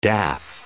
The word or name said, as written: daff